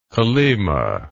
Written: kolyma

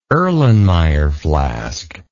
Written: Erlenmeyer flask

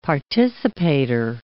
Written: participator

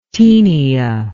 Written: tenia

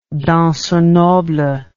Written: danseur noble

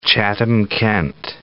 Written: Chatham-Kent

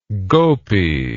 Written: gopi

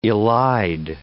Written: elide

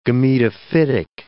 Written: gametophytic

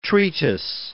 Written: treatise

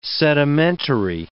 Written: sedimentary

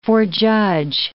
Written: forejudge

